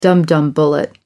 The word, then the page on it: dumdum bullet: American English